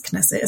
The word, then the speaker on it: Knesset: There have been marches in Israel outside the government, outside the Knesset, how you say it. It's Knesset, isn't it? – Yeah.